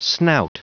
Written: snout

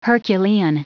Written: herculean

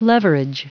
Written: leverage

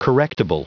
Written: correctable

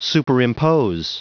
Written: superimpose

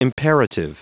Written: imperative

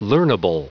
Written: learnable

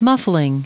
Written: muffling

